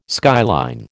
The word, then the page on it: skyline: American English